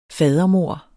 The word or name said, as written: fadermord